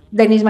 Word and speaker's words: Denise: En LinkedIn también me pueden encontrar como Denise Matenso Rubio. En Instagram estoy como denise.matienzo. Recuerden siempre que mi nombre se escribe con una N, con una S, con E al final y mi apellido con Z.